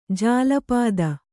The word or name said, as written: ಜಲ್ಪಿತ